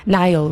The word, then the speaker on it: Niall: And I thought, because Niall rhymes with smile, we could try and get him to do it.